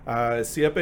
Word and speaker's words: Siepe: Siepe, it's always a good icebreaker. Most people don't know how to pronounce it. It's an Italian name, and the name means hedge in Italian.